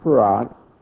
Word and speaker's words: Fard: It's spelled F-A-R-D. But it's pronounced F-A-R-A-D, Farag.